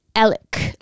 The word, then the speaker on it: Alec: And then his name is Alex, the dad, but he goes by Alec. Like it's spelled Alex. But you pronounce it like Alec.